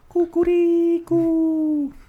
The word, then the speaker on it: kukuriku: The actual proper way to say a rooster sound in Croatian is kukuriku. – What? – That's how That's how roosters talk in Croatian. – That's kukuriku. – So when you, that's the sound they make. – Kukuriku.